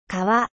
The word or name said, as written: 川